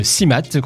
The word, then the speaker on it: CMAT: Ben, CMAT, donc CIMAT qu'on prononce.